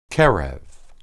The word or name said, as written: qereb